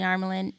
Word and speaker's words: Armelin: Or they say Armelin or Armelian.